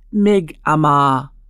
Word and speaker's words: Mi'kmaq: Mi'kmaq is pronounced Mi'kmaq. It is also spelled M-I-G-M-A-G, M-I-C-M-A-C, and M-I apostrophe K-M-A-Q.